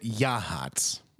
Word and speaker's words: Yachats: It's spelled Yachts. – Yachts. – But they think that you pronounce it Yahats. – Yachats. – It's Yachts. – What else is it? What else does that? What else makes that sound? Where CH is a hot. Yachats.